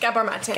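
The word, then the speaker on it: Gabor Maté: That actually ma- reminded me of, I say his name wrong, so is it Gabor Mate? – Gabor Mate.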